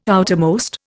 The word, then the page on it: outermost: Indian